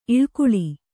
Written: ಇಲಿಗುಲಿ

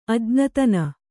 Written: ಅಜ್ಞತನ

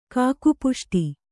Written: ಕಾಕಪುಷ್ಟ

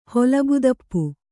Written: ಹೊಲಬುತಪ್ಪು

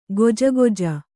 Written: ಗೊಜಗೊಜ

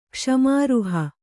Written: ಕ್ಷಮಾರ್ಹ

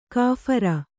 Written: ಕಾಫಿರ

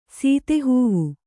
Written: ಸೀತೆಹೂವು

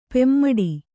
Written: ಪೆಮ್ಮಾಡಿ

